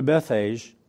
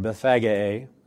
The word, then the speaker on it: Bethphage: And when they drew near to Jerusalem and had come to Bethphage, or Bethphage, as some pronounce it,